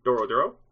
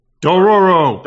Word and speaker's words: Dororo: Hyakumaru from Dorodoro. – Dororo.